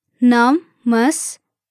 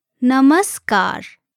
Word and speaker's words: Namaskar: Namaskar. Namaskar.